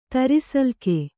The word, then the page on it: ತರಿಸಲ್ಕೆ: Kannada